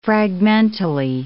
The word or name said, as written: fragmentally